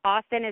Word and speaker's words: Austen: Yeah, on Twitter, you can follow me at Emily Austen TV. Austen is spelled A-U-S-T-E-N, Emily Austen TV. And then on Insta, at Emily Austen, A-U-S-T-E-P-E-N.